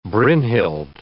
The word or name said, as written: Brynhild